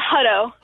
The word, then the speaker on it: Hutto: Hutto, Texas, on Facebook. – Hutto. – Hi. How are you? – Did I pronounce it all right? How did I do? – It's okay. Hutto.